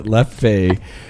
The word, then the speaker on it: Leffe: I believe this is pronounced Leffe, or... Leafy or Leafy. Leafy. Leafy. I love it. – I will choose to call it Leffe.